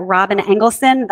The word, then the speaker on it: Robyn Engelson: You can reach me all over social media platforms at Robyn Engelson. I'll spell that. It's R-O-B-Y-N. Last name, E-N-G-E-L-S-O-N.